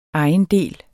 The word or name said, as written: ejendel